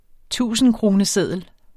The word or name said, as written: tusindkroneseddel